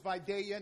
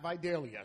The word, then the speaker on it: Vidalia: And as we always know, it's Vidalia, not Vidalia. So you heathens who try to pronounce it wrong, shame on you.